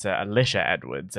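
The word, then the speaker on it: Alisha Edwards: Just a quick correction on pronunciation. It's Alicia Edwards. And the reason I need to correct you on that pronunciation is because there is a drag star called Alyssa Edwards.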